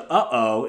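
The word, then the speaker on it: uh-oh (ʻŌʻū): And actually, the uh-oh, it's spelled O-U, it's a Hawaiian bird in the honeycreeper family.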